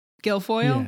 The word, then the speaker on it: Guilfoyle: Is that how you say her name? – Guilfoyle? – Yeah.